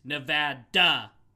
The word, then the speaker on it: Nevada: So the Vada, I realized I was pronouncing it wrong because it says some say tomato, some say tomato, but you can't say Nevada. No, way not ever. It's Nevada. Vada.